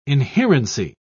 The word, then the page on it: inherency: British English